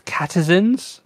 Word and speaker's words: Catizens: Uh, this one um, is just because of Stray, but there's Catizens, which I how do you even spell that? like citizens, but catizens.